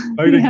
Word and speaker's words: Bowdoin: She graduated from, I think it's Bowdoin, is that the right pronunciation? Bowdoin College? – Bowdoin yeah.